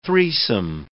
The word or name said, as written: threesome